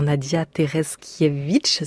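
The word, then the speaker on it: Tereszkiewicz: interprétée par Nadia Tereskiewicz, si je prononce bien. – C'est parfait.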